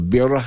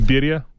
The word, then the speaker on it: birria: The birra, how do you pronounce it? – Birria.